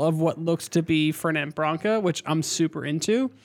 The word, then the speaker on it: Fernet Branca: How do you say it? – Fernet Branca. – So it's just exactly as spelt.